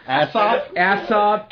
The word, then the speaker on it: Aesop: It's Aesop, I can't pronounce it even. – Aesop? – A-E-S-O-P, God. – Aesop.